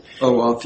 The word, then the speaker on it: OAuth: This is the user identifier issue and the district court's lateness of assertion basis for saying you cannot rely on the OAuth, is that how you pronounce it? – OAuth, yes.